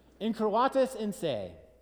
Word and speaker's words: incurvatus in se: and she came up to me and said, You said that wrong. If you want to sound wise, which is a good thing, I think, incurvatus in se and what it means is to curve in on oneself.